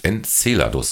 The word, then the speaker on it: Enceladus: Enceladus, so betont man es richtig. Ähm, der, das andere hört sich ein bisschen nach spanischen Maisgericht an.